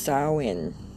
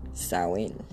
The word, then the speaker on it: Samhain: Pronounced Samhain. This is what this says. Most Wiccans refer to Halloween as Samhain, pronounced Samhain